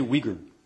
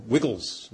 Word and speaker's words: Uyghur: How do you even say Uyghur? Wiggles, Wujis. I mean, one hears all kinds of very strange mispronunciations. Although most newspaper articles are careful to contain some kind of parenthetical line about who the Uyghurs are, and we say Uyghur, W-E-E-G-U-R.